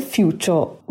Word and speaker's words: Futur: It's called The Future, but it's spelled F-U-T-U-R.